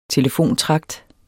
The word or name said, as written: telefontragt